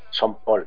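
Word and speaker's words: Sean: Yo tengo un problema con la pronunciación del nombre este de Son, bueno, lo de siempre. Que si Sin Connery, que si Son Connery, que si Son Paul. – Y es Son, porque lo dicen ellos.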